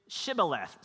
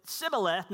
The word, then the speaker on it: shibboleth: They said this, all right, say shibboleth. Say that with me shibboleth. And we're not sure what that means. But if he said Sibboleth, not Shibboleth, because he could not pronounce the word correctly, they seized him and killed him at the fords of the Jordan.